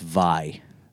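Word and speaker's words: Viy: Oh, and it's Vi.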